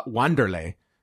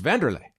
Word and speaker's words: Wanderlei: I mean, we don't we don't call uh, Wanderlei yeah. It's Vanderlei. It's Vanderlei.